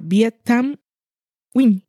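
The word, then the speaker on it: Viet Thanh Nguyen: Viet Tham Nguyen. Y yo lo he dicho así porque la pronunciación nunca diríais que es esa.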